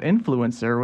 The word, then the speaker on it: INFLCR: Carolina announcing that they're launching an NIL exchange that's officially licensed by the university in partnership with a company called Influencer, which is spelled I-N-F-L-C-R.